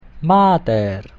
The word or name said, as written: mater